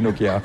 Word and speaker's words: Nokia: Mais il ne faut pas accentuer la prononciation de chaque lettre. – Non. – Nokia. – Non. On pourrait, mais c'est bon aussi Nokia.